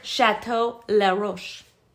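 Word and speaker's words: Chateau Laroche: Let me make sure I say it exactly correctly here. He called it... I was wrong. Chateau Leroche. Chateau Leroche.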